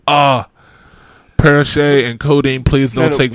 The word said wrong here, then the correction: Percocet: Percocet is how you say that.